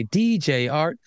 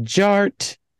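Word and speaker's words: DJ Artt: DJ Art, with two T's for a double dose of that tink tink. The D is silent, so it's just Jart.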